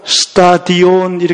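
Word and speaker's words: 스타디온 (στάδιον): 여기 나와 있는 운동장에서 다름질하는 자들이 다 달릴지라도 했을 때 이 운동장이라는 표현은 헬라어로 스타디온 이렇게 발음이 됩니다.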